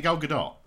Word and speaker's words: Gadot: Or is it Gal Gadot? Is that how you pronounce it? – Yeah, I think it's a very... Yeah, very light T. You pronounce the T, but not like.